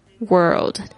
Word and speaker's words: world: The world 就是这个世界，特别注意一下 world 的发音，不是 word，它那个有一个 L 那个翘舌音。